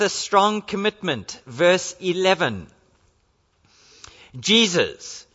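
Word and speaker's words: Jesús: Now, today, sometimes you get that name, although it's pronounced Jesus, in the Spanish culture, and it is a name that is still used there.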